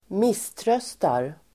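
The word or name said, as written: misströstar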